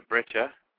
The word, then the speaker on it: breccia: It's a breccia.